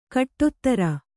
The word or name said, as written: ಖಡತರ